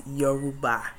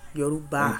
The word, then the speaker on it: Yoruba: Or even a Yoruba or anything. I kind of kept it at a description of something. – I have to correct you, my loved one. It's Yoruba. – Ah. – Yoruba.